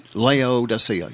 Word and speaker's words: Laodicea: Oh, I can't ever say it right. – Laodicea. – Laodicea. Yeah, there you go.